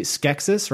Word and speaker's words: Skeksis: We did get to see Skeksis, right? That's how they're pronounced. Skeksis.